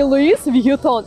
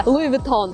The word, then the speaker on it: Louis Vuitton: Это не Луис Вьютон, это Луи Vuitton.